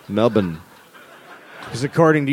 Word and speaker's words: Melbourne: Melbourne. – What? – They don't like to pronounce all the letters in their words.